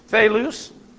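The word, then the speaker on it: Thelus (θῆλυς): So you got Arsane here, and Kai is, and then the Thelus, T-H-E-L-U-S.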